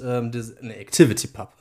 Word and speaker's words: ActivityPub: Ah, und jetzt haben wir ActivePub und ActivityPub. – Ja. – Mensch. Jetzt, als ich als Nerds hat natürlich direkt falsch ausgesprochen.